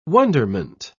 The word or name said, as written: wonderment